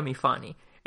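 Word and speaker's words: Myfanwy: es una A larga, que es una palabra llana, bueno, lo que en, español diríamos una palabra llana porque tiene la, eh, eh, la, acentuación en la segunda sílaba, entonces me está diciendo se pronuncia mi Fanny.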